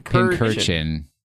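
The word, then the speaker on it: Pincurchin: Pincherkin.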